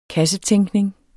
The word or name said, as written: kassetænkning